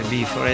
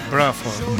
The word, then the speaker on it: Bruford: Algunos sí, algunos, por ejemplo, no es Bifford, es Bruford.